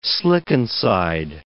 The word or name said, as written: slickenside